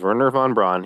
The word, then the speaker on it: Wernher von Braun: and I probably butchered his name many, many times, and I think I called him different names, but it is Wernher von Braun.